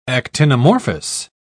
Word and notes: actinomorphous: British English

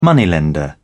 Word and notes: moneylender: British English

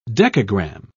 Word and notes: decagram: British English

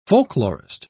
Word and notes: folklorist: British English

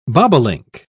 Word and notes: bobolink: British English

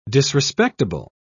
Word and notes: disrespectable: British English

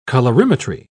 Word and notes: colorimetry: British English

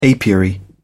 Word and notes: apiary: British English

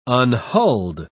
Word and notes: unhulled: British English

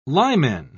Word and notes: limen: British English

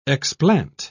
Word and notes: explant: British English

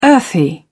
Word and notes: earthy: British English

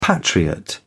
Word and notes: patriot: British English

